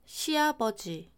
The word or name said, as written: 시아버지